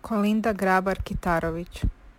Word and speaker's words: Kolinda Grabar-Kitarović: Kolinda Grabar-Kitarović.